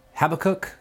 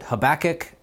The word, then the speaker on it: Habakkuk: My advisor, actually, in his introduction to the Old Testament, has said that some people pronounce the word Habakkuk, some people pronounce it Habakkuk, and some people that want to appreciate and preserve the Hebrew would say Habakkuk.